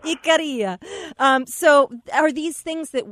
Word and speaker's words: Ikaria: And, of course, I'm saying Ikaria, and you're saying it so much more in such a better way. Tell me how to pronounce it one more time.